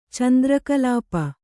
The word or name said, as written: ಚಂದ್ರಕಳಾಪ